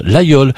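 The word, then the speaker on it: Laguiole: Ça s'écrit l'Aguiole, mais on prononce l'Aïol.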